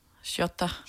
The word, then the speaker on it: Shorta: Så er det den der, vi har udtalt forkert, Shorta, som udtales... – Shorta.